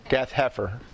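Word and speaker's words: Gath-hepher: Gath Helper? – Heifer. – Gath Heifer. – H-E-P-H-E-R.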